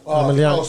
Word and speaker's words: Emelianenko: Emelianenko.